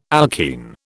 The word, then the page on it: alkene: British English